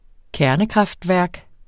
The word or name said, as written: kernekraftværk